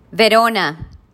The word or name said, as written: Verona